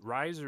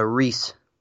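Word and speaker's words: Rhys: Matthew, is it Rise or Reese? – So Reese. – Reese, okay. – The hard S.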